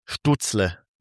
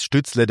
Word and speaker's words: Stützle: Ja, vor allem, wenn man dann die amerikanische Aussprache, ja, das ist zum Beispiel Stutzle, ne, der heißt Stützle, der Junge, und in Amerika können sie halt keine Ü sagen, der heißt Stutzle.